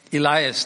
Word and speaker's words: Elias: I prefer to be called by my first name, Elias. That's how it's pronounced in English. In Greek, it's pronounced Helios. In the Old Testament, the Hebrew, it's Elijah. And it's pronounced rightly in your Malayalam Bible, Elia.